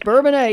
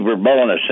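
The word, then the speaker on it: Bourbonnais: So, well, it used to be Bourbonus, and then we got a French mayor that came into town.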